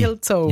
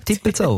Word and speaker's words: Tippilzou: Aber also der Urs, der wahrscheinlich dort wohnt oder einfach sehr verbandelt ist mit dem Ort, hat uns geschrieben, so wie er ihn aussprechen würde. Und er hat wirklich T-I-P-P-I-L-Z-O-U geschrieben. – Also ist Zelt, Zippi. – Dippelzau. – Ja, Dippelzau.